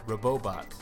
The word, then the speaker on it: Robobot: But I have been playing Kirby Planet Robobot. Robobot.